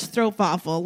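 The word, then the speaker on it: stroopwafel: In Dutch, strobe waffle. Like the W is more like a V.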